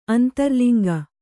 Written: ಅಂತರ್ಲಿಂಗ

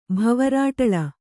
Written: ಭವರಾಟಳ

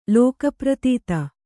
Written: ಲೋಕಪ್ರತೀತ

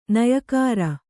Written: ನೆಯಿಕಾರ

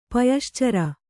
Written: ಪಯಶ್ಚರ

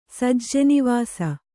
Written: ಸಜ್ಜೆನಿವಾಸ

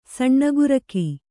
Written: ಸಣ್ಣಗುರಕಿ

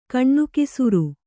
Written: ಗಣಾಕ್ಷರ

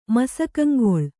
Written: ಮಸಕಂಗೊಳ್

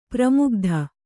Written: ಪರಮಗತಿ

